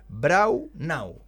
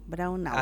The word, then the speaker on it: Braunau: Oye, me escriben acá, me dicen es Braunau. ¿Ah? – Braunau. – Así se pronuncia. Muy bien.